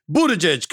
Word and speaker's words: Buttigieg: Buttigieg, correct? – You got it.